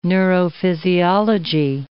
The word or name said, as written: neurophysiology